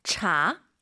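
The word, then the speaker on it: 茶: 이게 분명한 건 한글에 있는 그 차, 한국어의 차는 아니에요. 발음이 차 이거 아니에요. 약간 차 끈덕지게 차 그죠? – 是的. – 너무 또 우리가 오버해서 촤 이건 아니에요. 차.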